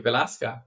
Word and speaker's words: Velasca: So really excited to have you today and to learn more about your company, Velasca. Am I saying that correctly? – Yeah, perfectly. Velasca. That's an excellent way to pronounce it.